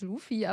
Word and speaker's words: Luffy: Deswegen sind auch die Netflix-Untertitel, da steht auch Ruffy mit ja. RU. Und so wurde es, glaube ich, im Deutschen auch ausgesprochen, aber das äh, ist falsch. Ich habe es auf Englisch geguckt und jetzt fällt mir das auch erstmal gerade ein.